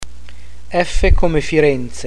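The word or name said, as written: F come Firenze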